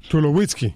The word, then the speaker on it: Tulowitzki: Y después cogieron un monstruo como si eres todo el bateador Tolosky del equipo del Colorado. – Tulowitzki. – Tolosky ese. – Tulowitzki. – Tolosky. – No. Tulowitzki. – Entonces la O se pronuncia U.